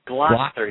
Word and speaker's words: Gloucester: did I say that right? Massachusetts. – Gloucester. You've got to get the New England thing going.